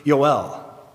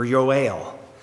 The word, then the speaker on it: Yoel: You would pronounce it in Hebrew, Yoel, or Yoel. We say Joel, because it's written J-O-E-L, and that's how we pronounce that.